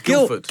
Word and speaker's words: Guildford: I love how you call it Guildford. You know the D is silent. – It's Guildford.